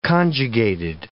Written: conjugated

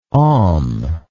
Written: homme

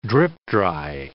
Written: drip-dry

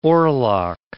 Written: oarlock

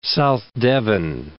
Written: South Devon

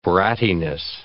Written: brattiness

